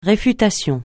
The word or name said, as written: réfutation